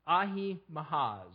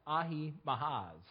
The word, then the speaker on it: Ahimaaz: Now, Ahimaaz. I haven't been saying that name very well, I think, as we've been reading it, but I think it's pronounced Ahimaaz.